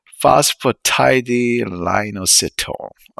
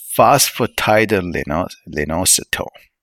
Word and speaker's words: phosphatidylinositol: Okay, phosphatidylinositol. All right, let me double check the pronunciation of the word. All right, that completely threw me off guard. Okay. Okay. Phosphatidylinositol.